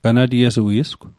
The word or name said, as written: ganatiesui'sgw